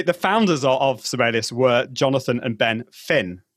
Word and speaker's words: Sibelius: I was doing some training for the correct pronunciation is Sibelius, just in case you were interested in that. He's a Finnish composer from Finland.